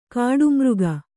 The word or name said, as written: ಗೌಡಮಾರ್ಗ